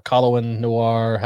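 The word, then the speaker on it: Colion Noir: Colin Noir. – Yeah, that's how you say his name.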